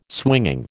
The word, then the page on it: swinging: American English